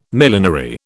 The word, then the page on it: millenary: British English